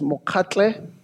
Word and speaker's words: Mokgatle: Uh, my last name is Mokgatle.